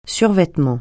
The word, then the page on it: survêtement: French